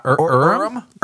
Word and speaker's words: Urim: Yeah, Urim and Thummim.